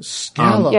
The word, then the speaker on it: Scala: It is the first time I've ever seen anybody really commit to Scala, but or Scala, however you pronounce that language. But Scala, yeah. Wow.